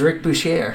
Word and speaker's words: Bucher: It was not Rick Bucher. It was Rick Boucher.